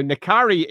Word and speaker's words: N'Kari: Um, although if you are a hard pronunciation expert, you'll know that the uh, dash, the dash, pardon me, the apostrophe makes it a glottal stop. So it should actually be Nkari.